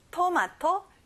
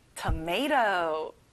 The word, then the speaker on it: tomato: tomato, tomato. 끝에 T 발음이 안 나요. tomato.